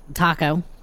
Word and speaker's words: Taco: My name's Taco. T-A-C-O.